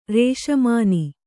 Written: ರಾಸಮನಿ

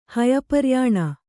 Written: ಹಯಪರ್ಯಾಣ

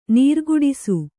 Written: ನೀರುಕುಡಿಸು

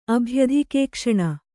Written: ಅಭ್ಯಧಿಕೇಕ್ಷಣ

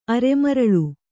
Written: ಅರೆಮರಳು

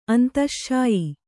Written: ಅಂತಶ್ಶಾಯಿ

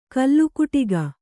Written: ಕಲ್ಲುಕುಟಿಗ

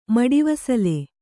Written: ಮಡಿವಸಲೆ

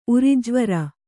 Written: ಉರಿಜ್ವರ

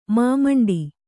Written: ಮಾಮಂಡಿ

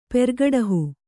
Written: ಪೆರ್ಗುಡಿಹಿ

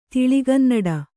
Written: ತೆಳುಗನ್ನಡ

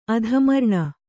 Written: ಅಧಮರ್ಣ

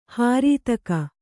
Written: ಹರಿತಕ

